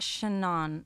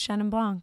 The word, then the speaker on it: Chenin: Chenin. It's not Chenon. – No. – How do you say it? – Yeah. – I just call it Chenin. – C-H-E-N-I-N.